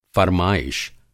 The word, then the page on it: फ़र्माइश: Hindi